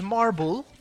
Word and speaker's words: Marbul: Father's name was Marble. – Marble. – Yes. – And is that mother, spelled like a marble? – M-A-R-B-U-L. – B-U-L. Marble. – And my mother's name was Nadina. – N-E-D-I-N-A.